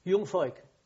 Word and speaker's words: Jungvolk: Jungvolk. – How do you spell it? – J-U-N-G-V-O-L-K. – Jungvolk. – Yeah.